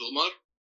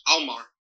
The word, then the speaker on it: Umar: Umar, who was the, or I can never pronounce that correctly, because it uses an Arabic sound, which is not present in English, Omar.